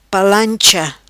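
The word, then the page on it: Palancha: patua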